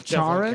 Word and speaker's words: Charon: I prefer Charon rather than Charon. – Yeah, it's Charon. Definitely Charon. It's no, the H is silent. – Charon. – It's Charon. K Carol.